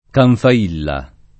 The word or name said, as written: Canfailla